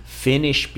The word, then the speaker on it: Finnish: For instance, Finnish people, which is spelled the same way as the verb to finish, right? But in this case with capital F and uh, double N, right? The pronunciation is still the same.